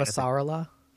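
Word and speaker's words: Avasarala: But I think the people who end up staying around are Fred and Ava Sarala, or how do you say it again? You said it right. – Ava Sarala. – Yeah, Ava Sarala.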